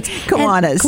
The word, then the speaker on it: Kiwanis: Am I saying that right? – You said it right. – Okay, I said it right. Great. – Kiwanis, yes.